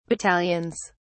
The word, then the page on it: battalions: British English